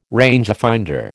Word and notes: rangefinder: American English

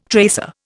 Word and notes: tracer: Indian

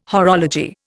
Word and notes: horology: Indian

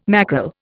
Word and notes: mackerel: Indian

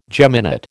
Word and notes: geminate: American English

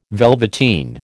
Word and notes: velveteen: American English

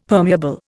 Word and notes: permeable: Indian

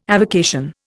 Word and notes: avocation: Indian

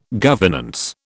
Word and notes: governance: British English

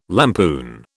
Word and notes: lampoon: British English

